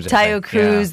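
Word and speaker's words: Taio Cruz: Tayo Cruz.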